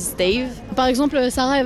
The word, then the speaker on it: CTAV: Si je vous dis stave Ok, ça s'écrit en réalité C-T-A-V comme c'est ta vie.